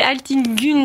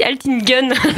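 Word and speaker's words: Altın Gün: Presque, c'est Altin Gun, du coup, ce qui est un petit tréma sur le U, et c'est comme en allemand, du coup, il se prononce U. Il y a des gens qui le prononcent aussi Altin Gun, du coup, ça fait penser à des flingues.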